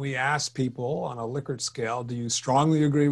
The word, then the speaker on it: Likert: And by the way, I used to call them Likert, but I learned that it's called Likert. Somebody told me once, hey, you're pronouncing it wrong. So I went around, did some research and found out that the daughter of Likert said it's Likert.